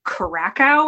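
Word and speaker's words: Curaçao: I, I I think I pronounced it Curacao or something. I'm from the Boston area. I have an awful accent. and it was I was and someone was like, "You mean Curacao?"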